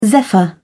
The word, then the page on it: zephyr: British English